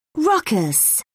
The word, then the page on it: ruckus: British English